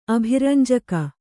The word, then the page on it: ಅಭಿರಂಜಕ: abhirañjaka